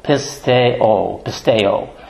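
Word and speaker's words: Pisteo, pisteo (πιστεύω): And, of course, the long mark over the O means that you pronounce the O. Pisteo, pisteo. That is how it is to be pronounced.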